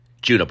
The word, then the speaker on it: Djuna: And I want to tell you folks right now, before we even get into this whole action, that it's spelled D-J-U-N-A, Juna Barnes, like Django Reinhardt.